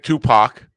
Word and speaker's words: Tupac: You would say it Tupac.